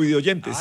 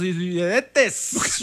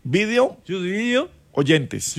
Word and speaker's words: videoyentes: Salude a los y pronuncie bien, por favor, YouTube videoyentes. – Ah, yo digo los videntes. – No, no, a ver, YouTube, video, oyentes.